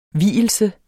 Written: vielse

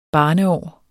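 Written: barneår

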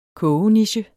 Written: kogeniche

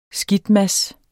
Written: skidtmads

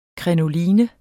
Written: krinoline

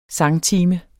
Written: sangtime

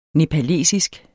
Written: nepalesisk

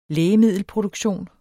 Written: lægemiddelproduktion

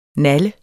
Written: naller

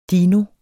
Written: dino

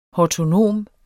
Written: hortonom

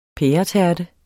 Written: pæretærte

